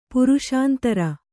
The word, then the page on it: ಪುರುಷಾಂತರ: kannada